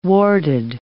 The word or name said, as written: warded